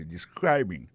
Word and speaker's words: describing: il a prononcé mot de describing accent tombé sur deuxième syllabe